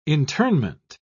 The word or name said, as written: internment